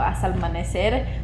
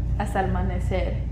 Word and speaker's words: hasta el amanecer: Like they have this thing called hasta el amanecer, which means like until the sun comes up, like they'll be partying. And I just, How do you say that again? – Hasta el amanecer.